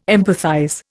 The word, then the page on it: empathize: Indian